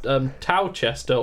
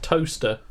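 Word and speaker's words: Towcester: We in Northampton, we have Towchester, or how it's actually pronounced, toaster. – Yeah, no, that is Towchester. – There's missing syllables. Just the forgotten syllables of toaster.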